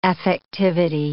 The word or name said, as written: affectivity